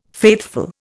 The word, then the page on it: faithful: Indian